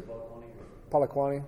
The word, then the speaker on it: Polokwane: Did I say that right? – It is Polokwane.